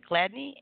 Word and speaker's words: Gladney: And also what inspired using Dion, and if I'm pronouncing it correctly, is it Gladney as your stage name? – Yes, ma'am. Yes. Gladney, G-L-A-D-N-E-Y.